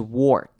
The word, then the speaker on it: work: The reliable workhorses，好了，看到 workhorses 的前面 work 这个字之前也有强调过，这个 or 我们并不会念成 or 的声音，而是会念成 er 的声音，所以工作这个字我们是念成 work，而不是 work。